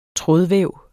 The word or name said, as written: trådvæv